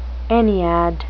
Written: ennead

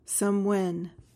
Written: somewhen

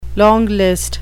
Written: longlist

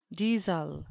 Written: deasil

